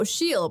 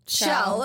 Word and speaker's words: Sheele: And it's spelled S-H-E-E-L-E. – Yeah. – Oh. – So I'm like, oh, Shiel. But then they said Shell.